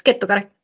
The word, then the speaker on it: Kettukari: I don't even try to like pronounce my last name, but it's Kettukari.